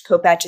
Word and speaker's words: Kopacz: I should know that, but I know that it's a link on my Instagram, which is my name, Anita Kopacz. Kopacz is spelled K-O-P-A-C-Z.